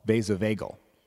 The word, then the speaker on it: vasovagal: But I have a condition that's called vasovagal. And that's how you pronounce it, basically. It sounds like bagel, but it is not.